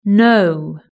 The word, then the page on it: know: British English